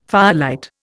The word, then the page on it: firelight: Indian